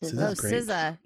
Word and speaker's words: SZA: SZA. Yeah. – Yeah. So I'm listening to her new album, obviously. Yeah, that's what I've been doing. – Very good. – Trying Speaking to of SZA, keep up with the world.